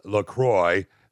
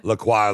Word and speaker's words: LaCroix: So officially it's pronounced LaCroix, so it rhymes with enjoy, not LaCroix, LaCroix, like the French might say it.